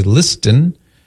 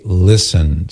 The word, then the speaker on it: listen: It just doesn't sound right if you pronounce the T. We don't say listen. – No. – We say listen.